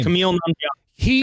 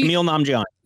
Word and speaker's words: Kumail Nanjiani: Kumail Nanjiani. Kumail Nanjiani.